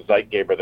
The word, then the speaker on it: Zeitgeber: Zeitgeber.